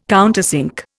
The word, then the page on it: countersink: Indian